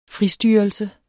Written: fristyrelse

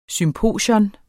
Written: SYMPOSION

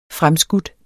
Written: fremskudt

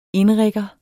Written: inrigger

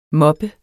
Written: moppe

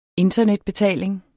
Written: internetbetaling

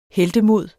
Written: heltemod

